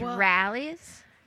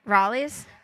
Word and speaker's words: Rally's: Oh, and I said it wrong at first. And he corrected me. It was Rally's. – Listen. – Not. Yeah. I was like, what did you say at first? – I said Raleigh's?